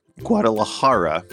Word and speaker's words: Guadalajara: It's got a silent J. Guadalajara.